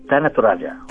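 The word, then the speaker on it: Tana Toraja: ¿Tanatoraja? ¿Se dice así? – Tanatoraja.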